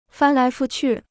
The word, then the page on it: 翻来覆去: fān lái fù qù